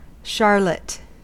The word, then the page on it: charlotte: American English